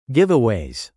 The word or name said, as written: giveaways